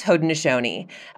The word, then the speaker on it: Haudenosaunee: And for Haudenosaunee, it's Haudenosaunee.